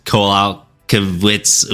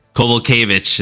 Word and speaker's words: Kowalkiewicz: Karolina Kowalkiewicz. Oof, already butchering the name. But Kowalkiewicz. – There you go. A little bit easier that way.